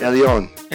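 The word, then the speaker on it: Elyon (עליון): I don't know how to pronounce that. – Elyon. – Elyon, yeah. Okay.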